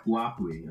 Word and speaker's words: Huawei: It's pronounced Huawei. It looks like Huawei or something like that.